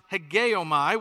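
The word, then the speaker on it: hegeomai (ἡγέομαι): So when he says the word consider, it comes from a Greek word that's pronounced hegeomai, which just means to lead or to rule over.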